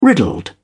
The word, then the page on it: riddled: British English